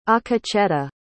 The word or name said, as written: akecheta